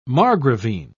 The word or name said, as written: margravine